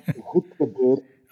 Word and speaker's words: Hudenberg: It's pronounced Hudenberg. – Okay. – But that's not easy for non-Dutch natives.